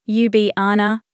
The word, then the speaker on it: Ljubljana: Ljubljana, Serbo-Croatian pronunciation, Ljubljana, Cyrillic, was the third and last Belgrade-class destroyer built for the Royal Yugoslav Navy.